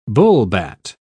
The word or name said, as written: bullbat